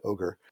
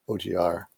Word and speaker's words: OGR: And there is a subsection of GDAL called OGR, which is an even worse acronym than GDAL, and people will variably pronounce it as OGR and OGR. It may stand for OpenJS Simple Features Reference Implementation. It may not, but people say OGR.